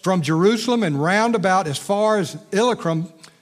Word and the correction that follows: Illyricum: I put the emphasis on the wrong syllable, Illyricum, and he said, I have fully preached the gospel of Christ.